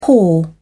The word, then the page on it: whore: British English